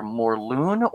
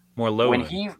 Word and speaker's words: Morlun: Which is also funny, because in the comics, when this is where pronunciation gets weird, it's either, depending on who you are, it's either Morloon or Morlun, one of the inheritors. – Morloon.